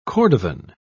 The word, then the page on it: cordovan: British English